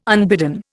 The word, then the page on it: unbidden: Indian